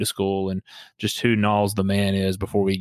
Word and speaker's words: Knollys: So it's Knowles, not Knollys.